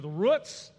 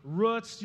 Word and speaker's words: roots: Some people choose to pronounce this word roots, you know, like kind of the rhyme with the double O sound in foot. Root, foot. It makes sense. I choose to make it kind of rhyme with boot, that double O sound, roots, boots.